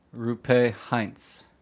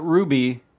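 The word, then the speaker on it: Roope Hintz: Rupe Hintz. – Rupe. – Rupe. Not Ruby. Not Rope.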